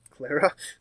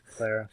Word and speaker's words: Clara: I was hoping for Carla from the Doctor Who. – Clara? – Clara. Carla. – Clara. Being around you, you just mispronounce names, you just keep going.